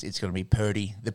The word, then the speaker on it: Purdy: Brock Purdy, who is their rookie quarterback who will play.